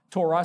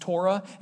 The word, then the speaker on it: Torah: Torah, sometimes it gets pronounced Torah, and it can be translated as law or instructions.